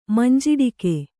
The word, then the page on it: ಮಂಜಿಟ್ಟಿಗೆ: manjiṭṭige